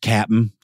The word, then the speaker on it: Captain D's: It's pronounced Cap'n. It's like Crunch.